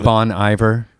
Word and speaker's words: Bon Iver: I believe it's pronounced Bon Iver. – Oh, sorry. Yes. – It was Bone. – It's Bone Iver. – I've heard it both.